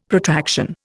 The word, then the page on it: Protraction: Indian